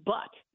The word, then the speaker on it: Bout: He was given the option of trading the Merchant of Death, whose name is Viktor Anatolievich, B-O-U-T, it's spelled in Russia, it's pronounced butt. So Viktor Butt.